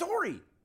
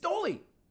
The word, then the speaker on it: Doli: Not, it's not Dory. It's Dolly. D-O-L-I. – Come on. – No, it is. And it's pronounced Dolly.